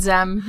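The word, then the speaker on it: Xzem: It's X-Z-E-M. – I pronounce it Zem. The X is silent. We can't pronounce these names. Who cares? – Exam, I'm going to say.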